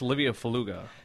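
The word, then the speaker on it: Livio Felluga: So it's Livia Feluga.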